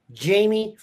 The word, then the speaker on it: Jaime: Alpha Dog named Jamie Ffrench. Not spelled like you'd think. Jamie, in fact, FF, it's actually J-A-I-M-E, FF. R-E-N-C-H.